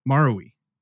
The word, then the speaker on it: Marui: I found a movie called Marui Video. I think that's how you say it. Marui.